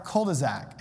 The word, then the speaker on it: cul-de-sac: I have said my whole life cul-de-sac, and I pronounce the S like it's a Z, cul-de-sac.